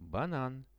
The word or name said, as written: banan